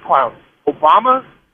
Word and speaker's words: Obama: I said, "Who's going to vote for this clown, Obama?" That rhymes with Osama.